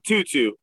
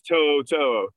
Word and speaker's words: To'o To'o: Yeah, it's not Tutu, it's To'o To'o for all of you who have no idea how to pronounce his name, which I also just learned today, even though I watched like seven Tennessee games last year from start to finish.